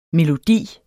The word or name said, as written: melodi